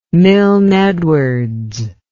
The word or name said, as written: milne edwards